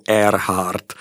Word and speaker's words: Earhart: Tak u její výslovnosti je kromě takový ty klasický nečitelný hatmatilky výslovnostní ještě přepis jako v čitelný podobě a je to jako Earhart. Ona je sice z T na konci, ale oni to tam mají jako air, jako vzduch.